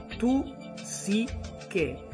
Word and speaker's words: tu sì che: Ecco, sembra quasi spagnolo, ma io ve l'ho pronunciato apposta, proprio staccato, tu sì che, in modo che è uguale all'italiano, cioè il sì scritto con l'accento sulla i. Perché è importante ricordare questa canzone di Sanremo del 1990?